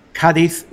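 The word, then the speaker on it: Cádiz: Which English people wrongly pronounce Cadiz. – Yes. – So if you're listening to us here, Cadiz is how you should pronounce it.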